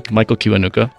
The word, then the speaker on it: Michael Kiwanuka: Michael Kiwanuka.